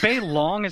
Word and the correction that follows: balisong: is the correct term from that, and butterfly is the anglicized term, I think? – Uh, it's ba- balisong. – Balisong. Okay, I just didn't know the pronunciation.